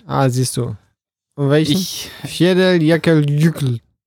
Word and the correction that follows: Eyjafjallajökull: Nein, Eyjafjallajökull. – Spricht er sich. – Eyjafjallajökull. – Irgendwie wird das T da weg. Ich habe mich zumindest versucht, etwas mit der Aussprache zu beschäftigen. – Das T ist stumm.